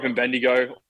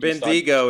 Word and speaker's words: Bendigo: But yeah, I grew up in Bendigo, or Bendigo, I think. – Bendigo is the way it's pronounced. Yeah.